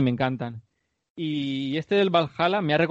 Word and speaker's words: Valhalla: Y es el que ha salido el tráiler de la historia de Assassin's Creed Valhalla. No sé si se pronuncia así. – Valhalla, sí. – Ah, eso, Valhalla.